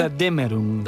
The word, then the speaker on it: Götterdämmerung: Aquest Götterdämmerung, ho pronuncio bé? – Götterdämmerung.